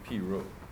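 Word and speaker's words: pirogue: G-U-E. Which is just pronounced P-Rogue.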